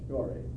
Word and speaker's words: Gorey: Is it Gorey you pronounce it? Yes, Gorey.